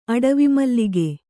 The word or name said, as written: ಅಡವಿಮಲ್ಲಿಗೆ